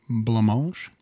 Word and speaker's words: Blancmange: We're going to go with Blancmange.